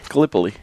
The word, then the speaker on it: Gallipoli: Gallipoli.